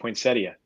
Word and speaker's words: poinsettia: Like poinsettia is pronounced poinsettia. No one believes you.